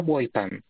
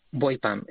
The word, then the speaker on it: Boipam: Vale, pues el subproyecto se llama Boipam. – Boypan. – Como es Boipam, si sí, el buey es de boya y el PAM es de Passive Acoustic Monitoring,